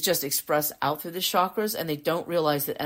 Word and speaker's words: chakras: And the first thing you need to know is the traditional pronunciation is chakras, not chakras. Chakras is the American pronunciation,